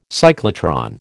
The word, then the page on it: cyclotron: American English